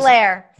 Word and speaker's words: Helaire: You know how many people mispronounce Clyde Edwards Alaire. – Yes. how do you Helaire? – Helaire. lair. – Helaire.